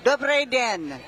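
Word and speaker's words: dobrý den: Dobre den.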